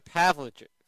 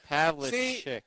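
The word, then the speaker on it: Pavlović: Yeah, so by the way, real quick, is Sasha Pavlovich. – There you go. – It's Pavlovich.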